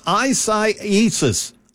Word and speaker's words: Isaias: It's I've listened to every meteorologist I can find to learn how to say this stupid name. But yes, it's Isaias.